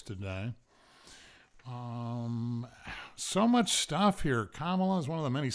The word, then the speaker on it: Kamala: Harris's first name is pronounced Kamala. It's derived from ancient Sanskrit and is a nod to her Indian heritage.